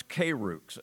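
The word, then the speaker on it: kairux (κῆρυξ): The word preach is a Greek word you pronounce kairux.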